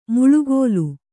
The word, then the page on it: ಮಳೆಗಾಲ: Kannada